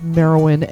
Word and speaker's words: Meriwyn: And my name is Marowyn, MERIWYN.